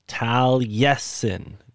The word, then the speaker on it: Taliesin: And this one is pronounced Taliesin. Taliesin. – I believe so. I could be butchering it again, but I think it's Taliesin. – Yeah, Taliesin.